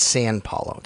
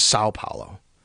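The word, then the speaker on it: São Paulo: I always say that wrong. It's not San Paulo. It's Sao Paulo, Brazil.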